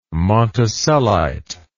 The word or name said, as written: monticellite